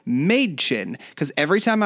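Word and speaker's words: Mädchen: But they named her Madchen because she says that's how it's pronounced, Madchen.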